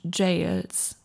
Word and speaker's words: gaol: Und dann gibt es Katakomben und Ruinen und Jails. Also G-A-O-L spricht man Jail aus, habe ich letztens gelernt.